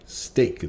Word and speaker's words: STEAC: STEAC. Steak is the way everybody likes to pronounce it.